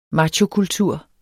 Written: machokultur

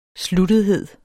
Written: sluttethed

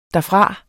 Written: derfra

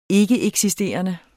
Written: ikkeeksisterende

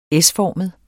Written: S-formet

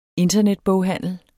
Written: internetboghandel

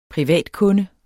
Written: privatkunde

